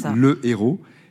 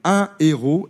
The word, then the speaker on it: héros: Donc on ne fait pas la liaison, on dit le héros. Et en revanche, ce n'est pas le cas pour le H de héroïne, car on dit des héroïnes. Particularité, en effet, dans la prononciation entre ces deux mots, qui ont pourtant bien la même signification et qui ont la même origine. On dit un héros et des héroïnes.